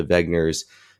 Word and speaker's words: Wegner: And Wegner is pronounced Wegner, but it's actually with a W.